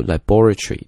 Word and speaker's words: laboratory: 英式英语的读法呢，读 laboratory，这个重音呢落在这个 bo 上面 laboratory。